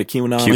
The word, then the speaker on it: QAnon: a QAnoner. And a Q, am I saying that right? A QAnon? – Yeah, QAnon.